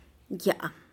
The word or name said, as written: yá'á